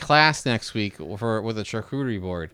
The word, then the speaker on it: charcuterie: Yeah, and that's like they're big into like charcuterie boards. I don't know if I'm even saying that right. – Yeah, no, you're good. – But like you know, the charcuterie is the big thing now.